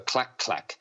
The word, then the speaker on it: Colac Colac: You know, we have a colac, but we also have a clack clack. But the clack clack is spelt colac colac. – Really? – but it's pronounced clack clack.